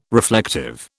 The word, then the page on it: reflective: British English